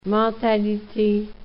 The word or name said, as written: mentalite